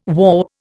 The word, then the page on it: whaup: Indian